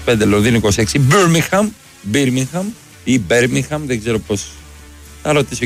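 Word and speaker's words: Birmingham: Οι πόλεις προφέρονται όπως λέει το στοίχημα. Πίτερμπρο, Πιτερμπόρο, Μίτλεσμπρο. Ό,τι γράφει εδώ πέρα το δελτίο. Μπέρμιγχαμ, τέλος. – Όχι, όταν είχα πρωτοέρθει. Μπίρμιγχαμ, έτσι λέγανε.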